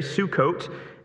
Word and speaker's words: Sukkot: It's the way I learned to pronounce that in seminary, Sukkot, and asks the people there to support him and his troops.